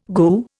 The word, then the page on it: goo: Indian